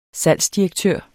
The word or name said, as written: salgsdirektør